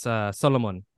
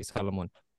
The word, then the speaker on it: Solomon: Also, it's there's also a different pronunciation to my name because my family is Ethiopian. Solomon is like a direct translation, but it's pronounced Solomon. My parents and my family call me Solomon.